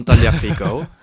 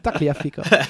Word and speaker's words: Tagliafico: Taliafico Taliafico was het, ja. – Maar dat Je moet heel erg uitkijken met de uitspraak hè, Fico. Tagliafico. – Tagliafico.